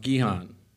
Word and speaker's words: Gihon: But I call it Gihon, but actually it's pronounced Gihon, G-I-H-O-N, Gihon.